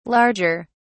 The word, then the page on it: larger: British English